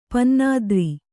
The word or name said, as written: ಪನ್ನಾದ್ರಿ